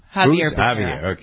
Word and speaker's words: Xavier: The Attorney General, how do you pronounce his name? Xavier Becerra. – Javier.